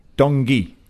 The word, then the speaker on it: Tanguy: It's been Tungay and Ndombele. – Dongi. Okay. – Dongi. – Dongi.